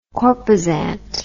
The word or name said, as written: corposant